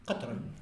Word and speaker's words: katran: Oui, c'est ça, Katran, mais moi je prononce mal. – Oui, Katran.